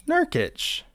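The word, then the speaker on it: Nurkić: Nurkic.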